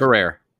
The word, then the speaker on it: Ferrer: They're in a house in Vegas, and Miguel Ferrer, or how do you say it? – Ferrer.